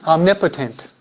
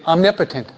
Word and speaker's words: omnipotent: You're putting the accent in the wrong place. Omnipotent. Say it. Omnipotent.